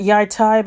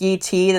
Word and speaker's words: Yi Ti: The far-off country of, I pronounce it Yaitai, but it's more commonly pronounced Yi Ti.